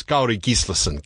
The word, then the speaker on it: Kári Gíslason: Normally with Icelandic, the correct way is to hit the first syllable. It took me a long while to realise that too. So the real way, and he'd say I was getting this wrong, is Kári Gíslason.